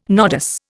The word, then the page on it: nodus: Indian